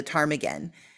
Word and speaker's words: ptarmigan: I use the the bird the ptarmigan because the p is silent.